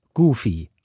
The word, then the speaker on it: Goofy: Das Wort Goofy wird Goofy ausgesprochen.